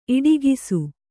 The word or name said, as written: ಇಡಿಗಿಸು